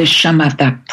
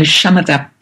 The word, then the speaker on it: Shamatha: And yes, am I saying that correctly? – Yes, the Shamatha Project. That's okay. indeed the way to say it.